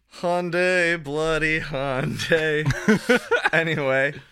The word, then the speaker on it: Hyundai: Driving your Hyundai. – You're driving your Nissan. – Ryan already established that he's driving a Hyundai. – Why are you pronouncing it like a British person? – Hyundai?